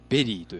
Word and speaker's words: 83113: ああ、じゃあ、英語なんだえっ、ー、と、 3 つ目は、えっ、ー、と、プロジェクト、えっ、ー、と、 83113 と書いて、ベリーと読むんですね。